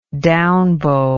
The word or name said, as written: downbow